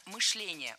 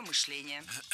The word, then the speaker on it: мышление: Мышление неверно. Правильно, мышление. Ударяем второй слог. Кстати, раньше допускалась двойная норма ударения в этом слове. Но сейчас вариант Сергея считается устаревшим, а литературная норма только мышление.